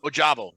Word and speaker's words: Ojabo: when Ohio State was on offense, they could not block Aiden Hutchinson or Ojabo. Am I pronouncing that correctly? – Ojabo? – Ojabo, sorry.